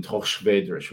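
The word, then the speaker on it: Torres Vedras: And I spoke Portuguese in a certain way because I lived in Tochvedres, which is the way they would pronounce it, which is about 40 miles outside of Lisbon.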